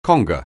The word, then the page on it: conga: anglais